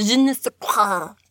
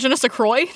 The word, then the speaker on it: je ne sais quoi: Ah, the je ne sais quoi. I gargled a little bit. – It's actually pronounced je ne sais quoi.